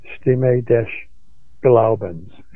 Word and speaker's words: Stimme des Glaubens: Stimme des Glaubens, S-T-I-M-M-E, for those who want to research it. D-E-S is the next word. G-L-A-U-B-E-N-S, Stimme des Glaubens,